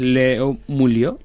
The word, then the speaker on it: Leo Mulio: Bueno, también está con nosotros, como decíamos antes, Leo Mulio. Lo he dicho bien, ¿verdad? – Sí.